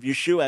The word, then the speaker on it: Yeshua: So that being said, Cindy, in many Messianic congregations, it's common to say Yeshua instead of Jesus as a reminder that we're talking about the Jewish Messiah. And that's great. I'm all for it. I will often speak in terms of Yeshua as I'm preaching, or as in praying sometimes relate to him as Yeshua.